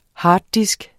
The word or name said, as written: harddisk